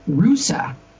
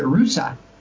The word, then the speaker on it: Russia: A town in Ohio that is named Russa. Which is spelled the same as Russia, but they pronounce it Russa.